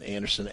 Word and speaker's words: Andersen: Keep in mind, my name is spelled J-O-N and Anderson, A-N-D-E-R-S-E-N.